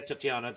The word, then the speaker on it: Titania: Titania, Tatiana, depending on how you pronounce it, yeah.